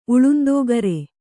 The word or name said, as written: ಉಳುಂದೋಗರೆ